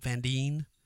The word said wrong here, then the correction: Van Dyne: Van Dyne, Van Dyne.